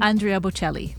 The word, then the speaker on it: Andrea Bocelli: Andrea Bocelli.